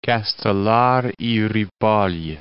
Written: Castelar y Ripoll

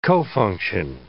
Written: cofunction

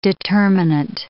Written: determinant

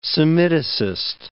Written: Semiticist

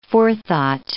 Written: forethought

